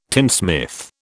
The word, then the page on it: tinsmith: British English